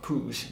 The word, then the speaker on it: Puig: I don't know how this is said in Argentine Spanish, but it is a Catalan surname, so I do know from my cousins that his surname is pronounced Puig, Maximiliano Puig.